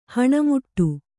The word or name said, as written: ಹಣಮುಟ್ಟು